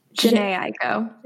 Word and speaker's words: Jhené Aiko: Is it Jenna Aiko? Jhené Aiko, Jhené Aiko.